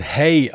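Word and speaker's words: Haigh: Also, die, die, die Aussprechweise des Nachnamens das war ja in London die ganze Sache, also im Englischen wird es wohl wirklich nur High ausgesprochen. Mhm. Dann gab es auch amerikanische Dokumentationen, da wurde es dann Hey ausgesprochen. Also ich habe mich jetzt für die englischsprachige Variante entschieden.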